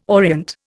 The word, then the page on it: orient: Indian